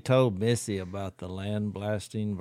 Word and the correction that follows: lambasted: versus... – Lambasted. – Lambasted.